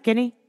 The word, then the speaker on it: Guinea: Like Guinea, did I say that right? Guinea? – Mm-hmm. – Okay, good.